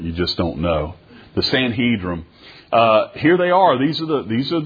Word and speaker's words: Sanhedrin: It's the Sanhedrin. I always pronounce that with an N at the end rather than an M. It's Sanhedrin.